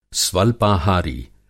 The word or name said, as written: स्वल्पाहारी